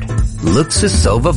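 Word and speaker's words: Luksusowa: Luxusova vodka. The number one selling potato vodka in the world. Looks for it at the LCBO. – Luxusova vodka.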